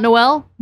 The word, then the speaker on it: Noel: Noel Fisher, I think it's Noel, not Noel.